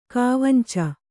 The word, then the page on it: ಕಾವಂಚಿ: kāvanci